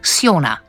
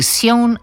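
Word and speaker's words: Sjón: In tudi literarni portret ob 22:07 bo prišel čisto blizu, predstavili bomo zelo slavnega islandskega pisatelja, enega najpopularnejših islandskih književnikov Sjona. Z njegovim imenom je kar nekaj preglavic, ampak tu nekje se je že usidrala ta izgovorjava, torej Sjon ali Sjon. Sjon pa raje ne.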